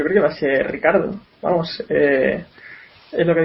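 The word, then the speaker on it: Ricciardo: Richard, Ricardo. Ricardo. Bueno, ojo, ojo, él dice que se eh, pronuncia Ricardo.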